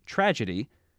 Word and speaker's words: tragedy: In tragedy, j is an open syllable followed by the stressed syllable d, so we get tragedy instead of tragedy.